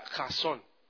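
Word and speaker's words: Jasón (חזון): Escribe K-H-A-Z-O-W-N. Y se pronuncia Jasón.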